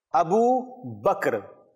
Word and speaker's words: Abu Bakr: The answer was given, the correct pronunciation is Abu Bakr. Abu Bakr. So, ba and kaf is joined. There is a sukoon on the kaf. Bakr. Not bakr. Bakr. You will join the ba with the kaf.